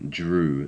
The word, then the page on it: drew: English